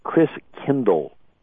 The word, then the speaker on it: Christkindl: And the German pronunciation of Christ child is Christkindl, like kindergarten, kindercare. Kindl means child.